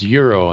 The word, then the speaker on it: gyro: As in the currency, it's pronounced euro in the Midwest. – Oh, really? – No G. – Wow. I've never heard it pronounced that way. – Euro? Yeah, euro.